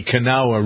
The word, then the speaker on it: Kanawha: So the Elk River, it seems, is a tributary of the Kanawha. Is that how we pronounce it? K-A-N-A-W-H-A. Sounds like an Indian name. – Mm-hmm. – The Kanawha River.